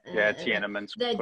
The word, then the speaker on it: Tiananmen Square: I can never pronounce it, Tiananmen Square. – Tiananmen Square. – Thank you, the massive massacre.